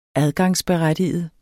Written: adgangsberettiget

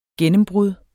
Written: gennembrud

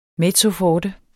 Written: mezzoforte

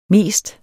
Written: mest

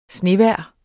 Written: snevejr

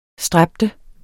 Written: stræbe

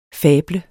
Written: fable

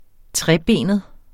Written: trebenet

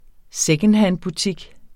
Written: secondhandbutik

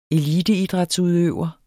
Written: eliteidrætsudøver

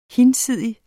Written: hinsidig

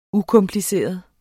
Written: ukompliceret

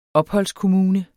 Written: opholdskommune

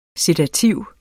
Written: sedativ

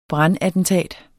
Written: brandattentat

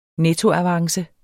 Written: nettoavance

